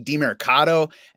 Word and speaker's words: DeMercado: I would not take him this week over Imari DeMercado, and that's the pronunciation according to the Pro Football Reference key. So I'm going to go with that.